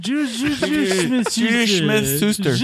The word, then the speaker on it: Juju Smith-Schuster: Juju Smith-Schuster.